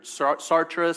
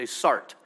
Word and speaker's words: Sartre: I'm sure that many of you guys have heard Sartre. Some of you guys will mispronounce it and say Sartre.